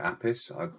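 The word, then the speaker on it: Appice: Apis. – ICE. Sorry. – Yeah, Apis.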